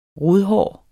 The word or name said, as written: rodhår